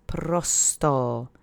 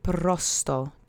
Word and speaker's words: prosto: Let's say prosto together three times. Accent is on the first syllable. Try to roll that R if you can. Prosto.